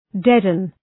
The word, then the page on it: deaden: ‘dedən